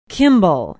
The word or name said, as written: kimball